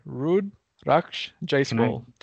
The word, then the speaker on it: Rudraksh Jaiswal: Rudraksh Jaiswal. I butchered it again. That's the H that gets me. Rudraksh Jaiswal.